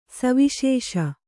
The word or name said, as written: ಸವಿಶೇಷ